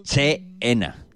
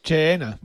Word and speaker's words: China: Dicho para los escuchantes, pues, Che-ena. – Che-ena. – Pero nosotros, como te pronunciamos muy bien en inglés, decimos China.